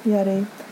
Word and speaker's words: Jettie: But J's in Dutch are often pronounced like Y's. – Yeah. – Yeti.